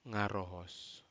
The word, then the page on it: garohos: Aneityum